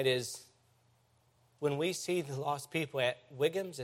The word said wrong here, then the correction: Wegmans: is that how you pronounce that grocery store? Wegmans. Yes, that. Wegmans.